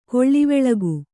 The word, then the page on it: ಕೊಳವಾಳಿಕೆ: Kannada